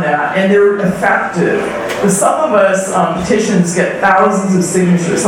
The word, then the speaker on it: SumOfUs: It's spelled as one word, Some of Us. Some of us, some of you know about it. S-U-M-O-F-U-S.